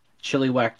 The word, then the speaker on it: Chilliwack: And you just started a new position with the Chilliwack Chiefs.